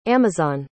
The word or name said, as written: amazon